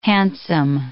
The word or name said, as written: hansom